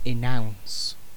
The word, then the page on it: enounce: British English